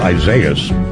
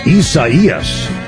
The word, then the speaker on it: Isaias: Yeah, the way it's written, I thought it was Isaias. According to the pronunciation breakdown, they're saying it's pronounced Isaias.